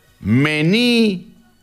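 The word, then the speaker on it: menu: ¿Es menú, mení o mené? Me dijo, entre las... Así, coloque la huella, así me dijo, así. Se pronuncia mení. Mení, me dijo, mení. Sí. Así como mení. Entre U y E.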